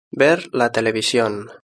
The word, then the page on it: ver la television: European Spanish